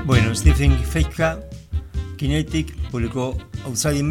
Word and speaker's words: Feifke: Decíamos el líder de la banda, el pianista, conductor, arreglador, compositor, Stephen Fechka, así se pronuncia, tiene apenas 29 años.